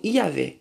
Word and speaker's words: Iavé: A Moisés apresenta-se nominalmente, o seu nome misterioso, muitas vezes pronunciado como Iavé significa eu sou aquele que sou.